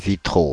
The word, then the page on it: vitraux: /vi.tʁo/